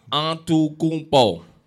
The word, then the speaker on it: Antetokounmpo: Giannis Antetokounmpo, just in case you people don't know how to say his name out there. Giannis Antetokounmpo.